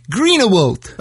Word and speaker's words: Greenawalt: It's Jake Greenawalt.